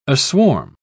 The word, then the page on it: aswarm: British English